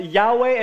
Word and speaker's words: Yahweh: The I am that's pronounced Yahweh and no one in Jewish culture says it because it's too holy to say, that I am?